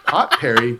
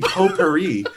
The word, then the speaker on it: potpourri: Pot Perry? No, the T is silent. It should be Potpourri.